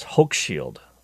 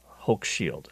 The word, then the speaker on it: Hochschild: because his name is actually spelled Hochschild, but it's Hochschild. Hochschild is how you pronounce it.